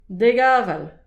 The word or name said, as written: dek aval